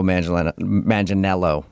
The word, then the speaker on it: Manganiello: Because we talked to him, and he told us that a lot of people say he looks like Joe Manganiello, right? That's how you say it? – Yeah. You got it. You nailed it.